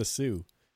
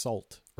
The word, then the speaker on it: Sault: and then we have uh, we have the Sioux, which is S A U L T, and it's not pronounced salt or salt.